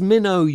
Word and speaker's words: Minow: Is Minow the proper pronunciation? – It depends if you're me or my husband. I think, as it's a French Huguenot name, it's Minow.